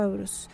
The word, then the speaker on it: Eurus: Justamente eso, bueno, él significa, su nombre es estremecer, es Eurus, realmente se pronuncia como Eurus.